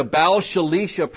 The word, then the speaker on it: Baal Shalisha: Because first fruits belongs to God, we looked at the Baal Shalisha principle. Now, I better spell that one for you. That's B-A-A-L-S-H-A-L-I-S-H-A. Baal Shalisha. B-A-A-L-S-H-A-L-I-S-H-A.